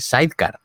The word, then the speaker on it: sidecar: el hombre lo pronuncia bien, dice sidecar.